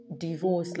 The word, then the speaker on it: Divorce: Divorce, like the D-I in deep. Like deep divorce.